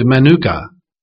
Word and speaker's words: Menuchah: Now, in the Old Testament, in Psalm 95, verses 8 through 10, the word rest is the Hebrew word manuka. Manuka, it's spelled M-E-N-U-C-H-A-H.